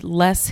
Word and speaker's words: Loess: I pronounced them both less, but. – Oh, okay. – Yeah. – So L-O-E-S-S Hills are the ones that are the geographic, whatever you want to call them.